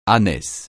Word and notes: ânesse: francais

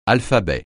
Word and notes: alphabet: francais

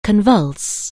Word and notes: convulse: anglais

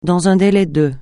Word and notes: dans un délai de: francais

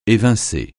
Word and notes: évincer: français

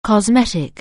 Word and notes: cosmetic: anglais

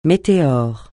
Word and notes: météore: francais

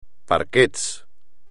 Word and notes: parquet: espagnol

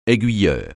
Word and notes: aiguilleur: français

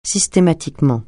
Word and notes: systématiquement: francais

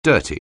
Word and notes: dirty: anglais

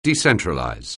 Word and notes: decentralize: anglais

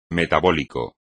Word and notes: metabólico: Spanish

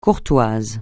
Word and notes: courtois: French

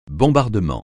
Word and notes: bombardement: francais

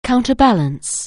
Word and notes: counterbalance: anglais

